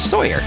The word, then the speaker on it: Tsoir: And just for those of you who may wonder, how would you misspell Sawyer? It's T-S-O-I-R, which is exactly how I would have pronounced T-S-O-I-R, is Sawyer.